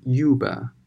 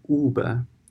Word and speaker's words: Uber: Uber U B E R 要注意这个单词的发音，它呢不读成 Uber，而是 Uber。 The company Uber has cut three hundred and fifty jobs。啊，就是这篇文章的首句呢，就告诉我们，优步呢已经裁了三百五十个岗位了。 Uber CEO，CEO 呢是什么意思呢？